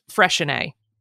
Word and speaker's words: Freixenet: And it's pronounced Freshenet. So mm-hmm. it's like a CH instead of an X.